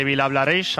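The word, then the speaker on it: Vilablareix: Gracias, Santi, desde Villablareix. Ahora sí que lo he dicho bien.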